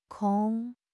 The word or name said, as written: 孔